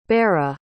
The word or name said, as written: Berra